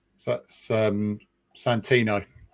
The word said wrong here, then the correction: Sannino: It's bad pronunciation, but it's... – it's Giuseppe Sannino.